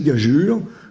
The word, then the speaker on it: gageure: c'est gageure. Le e est ici pour faire prononcer le g.